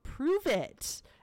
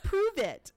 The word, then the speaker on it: Pruvit: So from Modare, she is now in a MLM called Prove It. And you have to say it like that because there's an exclamation point at the end. Prove It. And it's spelled P-R-U-V-I-T.